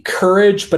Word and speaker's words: Curedge: So, if you want to support it, um, the Instagram page is at Courage, but it's spelled C U R E D G E.